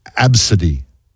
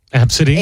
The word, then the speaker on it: ABCDE: The number 1 name that's so bad you can't believe a parent actually gave it to their own child is Absidy. – Absidy? – A-B-C-D-E. – Uh-huh. Absidy. A-B-C-D-E. That's how you spell it. A-B-C-D-E.